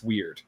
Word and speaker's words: weird: I think that it works out in the bargain, though, because weird is pronounced weird. The word should have an umlaut over the I because it should be pronounced with two syllables. And if you look at any of the lines the weird sisters are involved in, it's a two-syllable word.